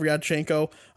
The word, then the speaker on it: Ryabchenko: Vladislav Ryabchenko. You kind of say it like together, like Riad. It's kind of, you know, like awkward sounding, but like you don't pronounce them as separate syllables, but it's Ryabchenko.